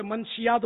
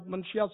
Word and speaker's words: منشیات: عام طور پہ منشیات بولتے ہیں ہم لوگ، منشیات منشیات منشیات سنتے ہیں، منشیات نہیں ہے، منشیات